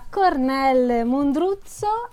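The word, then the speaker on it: Kornél Mundruczó: Allora io vi parlo di Peace of a Woman, diretto da Cornel Mondruzzo, a quanto pare si pronuncia così il nome di questo regista ungherese che eh,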